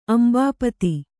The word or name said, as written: ಅಂಬಾಪತಿ